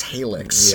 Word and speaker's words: Halyx: H-A-L-Y-X. Helix. Yeah. Okay. Yeah, they were at Tomorrowland in Disneyland. – Yep. H-A-L-Y-X.